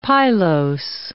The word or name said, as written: pilose